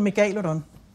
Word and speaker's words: megalodon: Jeg plejer at bruge de latinske udtaleregler. – Mm. – Så hedder den megalodon. Men af en eller anden grund, så har vi danskere bare lyst til at sige megalodon, eller megalodon.